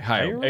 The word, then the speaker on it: Hayao: Hayao Miyazaki. – Hayao, okay. – Hayao. – Hayao? – There's no R. – Okay, Hayao. – Hey, here, I'll spell it for you. H-A-Y-A-O.